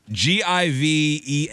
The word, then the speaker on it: Givenchy: Apparently, fellas, we pronounced, or I, and specifically, pronounced that French fashion designer wrong. You know, with the three-toed sandals. – It's not Givenchy. – It's a Versace. – Givenchy code. – Givenchy. Somebody goes, you are not saying that right.